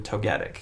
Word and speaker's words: Togetic: They only released 7 of them, but it's technically 8 when you include Togetic.